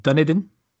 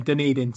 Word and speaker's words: Dunedin: Okay, let's say 117 versus New Zealand at the University Oval in Dunedin. 6th of March 2013. – Definitely how it's pronounced. – Absolutely. In Dunedin, 6th of March 2013.